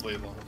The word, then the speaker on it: Leilon: Lelion was south. Lelon. Lelon. Leilon. It has a bunch of pronunciations, but people who live there, you know, they know it's Leilon.